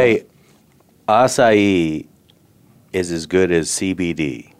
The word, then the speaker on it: acai: A-C-A-I. – It's acai.